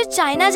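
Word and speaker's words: Qin: अगर कोई Chinese word या नाम में इंग्लिश का क्यू लेटर आता है तो उसे च प्रोनाउंस करते हैं। जैसे क्यू आई को कहते हैं चाय, क्यू आई एन को कहते हैं चाइन। सो दैट्स वाई क्विन डायनेस्टी से नाम पड़ा चाइना।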